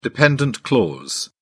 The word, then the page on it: dependent clause: British English